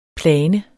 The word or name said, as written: plan